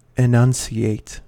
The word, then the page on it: enunciate: American English